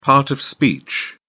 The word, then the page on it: part of speech: British English